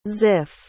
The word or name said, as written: ziff